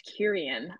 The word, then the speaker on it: Quirion: my na- last name is pronounced Kyrian.